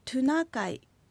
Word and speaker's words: トゥナカイ: はい、本当はトナカイではなくトゥナカイという発音になります。 – トゥナカイ、トじゃなくてトゥ、トに丸がついた形のトゥという発音になるんですね。